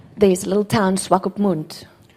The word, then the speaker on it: Swakopmund: Okay, slowly. – Swakopmund. – Swakopmund. – That's good.